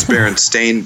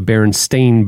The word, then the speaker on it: Berenstain: Well, I remember it as Bernstein Bears because I remember the cartoon show and them pronouncing it that way, but I still thought it was spelled E-I-N. – Yeah. – Wow. It's actually, I taught him how to pronounce it by, you know, pointing out all the stains that he causes and like, you know, I'm going to call you a Berenstain Bear and shame every time you dribble something on the carpet.